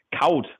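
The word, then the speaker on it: Couto: Wenngleich man dazu sagen muss, dass mit den Leihspielern Svensson und Jan Kaut, so wie er jetzt aktuell ausgesprochen wird, ja zwei Spieler schon fest verpflichtet wurden.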